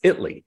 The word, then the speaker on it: Italy: By the way, I it's spelled Italy, but it's pronounced Italy.